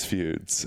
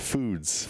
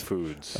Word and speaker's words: Foods: Genius Feuds. Feuds? – Feuds. – How do you pronounce that word? – That's a different book. That's the follow-up. – Okay, good. Yeah, you pronounced that word foods.